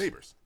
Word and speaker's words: Nabors: Is it pronounced Nabors? – Nabors.